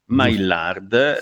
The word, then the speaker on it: Maillard: Maillard, sì.